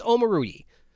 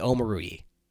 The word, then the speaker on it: Omoruyi: no, it's Omoruyi. So I guess that's how you say his name. You say Omoruyi.